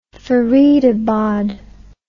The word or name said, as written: Faridabad